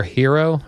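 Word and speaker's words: Herro: He appeared on WQAM radio and said, "Tyler Hero, is that how you say his name?" – Yes.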